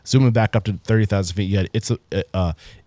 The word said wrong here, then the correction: Il Terrazzo: Did I say that correctly? – Il Terrazzo. – Il Terrazzo.